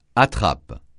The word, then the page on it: attrape: francais